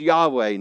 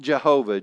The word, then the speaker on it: Yahweh: But it's probably best pronounced Yahweh, not Jehovah.